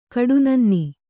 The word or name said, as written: ಕಡುನನ್ನಿ